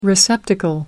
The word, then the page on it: receptacle: American English